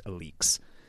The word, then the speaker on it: Alyx: That's Alyx, by the way, if you've ever seen that brand. I didn't know how to pronounce it for a while. It's pronounced Alix.